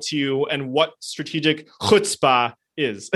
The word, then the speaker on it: chutzpah: It is a chutzpah. No, you got to get totally be like an old Jewish man with the...